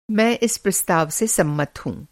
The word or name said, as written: सम्मत